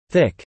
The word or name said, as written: thick